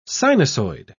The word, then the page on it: sinusoid: British English